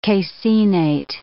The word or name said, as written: caseinate